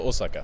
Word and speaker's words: Osaka: Och eh, när det gäller Right to Dream då, som spelar i eh, snart här då mot eh, Orasaka, ursäkta uttalet här, du kanske kan uttala det bättre? – Ser det så Osaka.